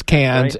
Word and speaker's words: Cairns: Well, you just mentioned Cairns, and it is you pronounced it correctly. You look at it, it looks like, oh, that must be spelled Cairns, but no, it is Cairns, right.